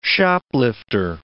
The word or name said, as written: shoplifter